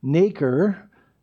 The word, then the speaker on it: nacre: It secretes this fluid called nacre, N-A-C-R-E. It's pronounced like baker, but it's nacre.